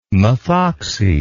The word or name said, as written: methoxy